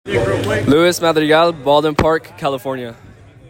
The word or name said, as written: Lewis Madrigal